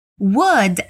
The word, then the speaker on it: would: Would é W-O-U-L-D e could é C-O-U-L-D. Na hora da pronúncia, o L se torna quase mudo e o O, U tem som de U.